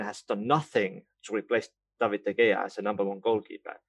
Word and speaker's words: David De Gea: No, David De Gea, let's pronounce it correctly.